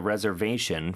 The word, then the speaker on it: reservation: Reservation. 자, 강세 들리셨죠? 그리고 S 발음은 Z 발음으로 reservation.